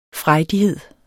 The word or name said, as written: frejdighed